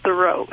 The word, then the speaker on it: Thoreau: Should be pronounced Thoreau.